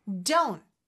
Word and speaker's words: don't: Don't. T 소리가 안 나죠? Don't. 물론 don't. T 발음 내도 되지만 보통은 그냥 don't 이렇게 끊어주는 느낌으로 해주시면 돼요. Don't forget.